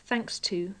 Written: thanks to

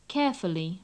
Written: carefully

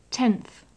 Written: tenth

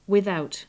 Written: without

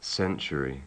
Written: century

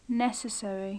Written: necessary

